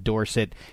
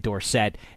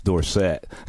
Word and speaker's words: Dorsett: And I said, is it cool if I still go with the old school pronunciation of Dorsett instead of the fancified Dorsett? And he said, please do go with that.